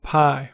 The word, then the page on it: pi: American English